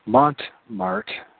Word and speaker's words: Montmartre: they made their vow in the church of Montemor. See if I'm saying that right. Montmartre. That's Montmartre. I don't know, something like that.